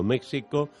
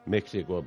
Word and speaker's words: México: Va a viajar este año a Berlín, Londres, París, Moscú, Pekín, Tokio, Buenos Aires, Montevideo, México... México, ¿por qué digo México? – México. – Tampoco digo Quixote, ¿no? Que se escribe con este, Quijote.